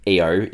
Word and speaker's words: Io: IO or EO is the fifth of Jupiter's satellites and the third largest, so it's a moon, and you can pronounce it either way,